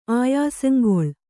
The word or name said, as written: ಆಯಸಂಗೊಳ್